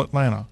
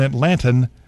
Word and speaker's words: Atlanta: And that's All A-L-L and then A-L-L Atlanta, All Atlanta. You know, if you're truly an Atlantan, the way you pronounce that capital city in Georgia is not at with a T, Atlanta, it's Atlanta, Atlanta. Atlanta. It's like it doesn't have a T in there.